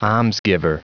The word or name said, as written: almsgiver